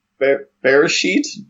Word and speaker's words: Beresheet: Oh, it's pronounced bear shit, I think. – I don't think that's correct. – It's spelled B-E-R-E-S-H-E-E-T. – I would have said... Bear sheet? – No, I think it's... Bear sheet? No, I talked to someone in Israel, and they were saying it's bear shit.